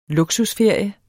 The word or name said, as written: luksusferie